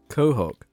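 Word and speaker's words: Quahog: Cohog.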